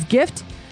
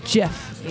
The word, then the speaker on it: GIF: So many people have been gracious enough to praise his gift. The music is here to stay. – I pronounced GIF. – Oh, GIF. Sorry. Mm-hmm. I thought it was JIF.